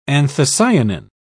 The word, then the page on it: anthocyanin: British English